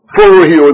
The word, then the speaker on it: phroureo: Now the word kept is the word phroureo in the Greek. I'll spell it for you. P-H-R-O-U-R-E-O. P-H-R-O-U-R-E-O.